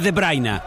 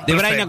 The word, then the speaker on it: De Bruyne: Pensé que le iba a decir De Bruyne, porque mi hijo le dice De Bruyne. – Pero es De Bruyne. – De Bruyne. – De Bruyne, como lo pronuncia Mariano Clos.